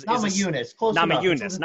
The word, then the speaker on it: Namajunas: Namajunas.